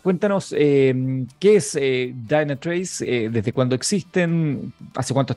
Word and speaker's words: Dynatrace: Es Dynatrace. – Dynatrace.